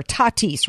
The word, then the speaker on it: Tatis: And then I find out, I did know though, this local guy here that was like supposedly our best player, Tatis, right? That's how you say his name, shortstop.